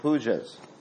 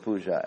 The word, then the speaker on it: puja: pujas. Incidentally, don't pronounce the word puja as puj. Puj means stool.